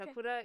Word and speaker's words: Takura: Takura is like it's spelled T as it said, T-A-K-U-R-A.